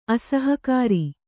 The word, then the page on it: ಅಸಹಕಾರ: asahakāra